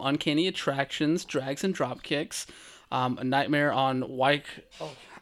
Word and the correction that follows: Wyckoff: I always mess up the street name because it's spelled awkwardly. A Nightmare on Wyckoff Avenue. There we go.